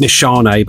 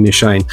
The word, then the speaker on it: Nishane: Alexandria Fragrances Nefarious is a clone of Nishane or Nishane, however you pronounce that shit. Mm-hmm. Apparently it's Nishane, but I can't help saying Nishane.